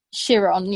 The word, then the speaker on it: Chiron: One with a Bugatti, I can't pronounce it right, Chiron? – Chiron, yeah.